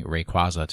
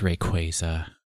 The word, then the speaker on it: Rayquaza: I just correct my coworker's mispronunciations of Pokemon names when he comes in to say, I heard that they're adding Rayquaza to the raids. And I just turn and say, it's Rayquaza. – Please, sir. Rayquaza.